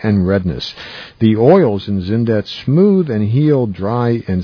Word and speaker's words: Xyndet: There are three Zindet products, a sensitive skin formula cleansing bar, a hair and body shampoo, and a skin balm. Zindet, that's spelled XYNDET is truly unique.